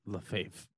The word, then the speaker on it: Lefebvre: Lefebvre. The B and the R do not exist when you look at his name. There's a B and an R that you do not pronounce.